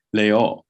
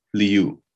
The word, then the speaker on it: Liu: but they say Leo. Oh. It has pitch difference in it. And it's actually A-O with an open O, Leo. So however you say her name, it should be two syllables, Liu.